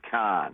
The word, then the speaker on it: Cannes: You can say Cannes, or you can say Cannes, but it's really somewhere in the middle there. It's sort of Cannes.